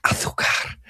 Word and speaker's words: azúcar: Or azúcar, I don't know how you say it in the Americas, but it's azúcar in Iberian Spanish.